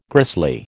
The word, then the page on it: grisly: American English